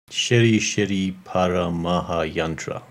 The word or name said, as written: Shrii Shrii Para Maha Yantra